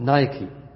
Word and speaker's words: Nike: نايكي، هكذا تنطق نايكي، NIKE، وترجمتها آلهة النصر عند الإغريق.